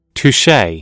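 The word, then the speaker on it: touché: Touche. – I said it right.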